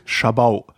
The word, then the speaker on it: Jabot: J-A-B-O-T. – J-A-B-O-T. Jabot. Schabau.